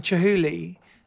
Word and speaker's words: Chihuly: Of Chihuly. I can never say it right, but is that right? – Right? – Yeah, that's right.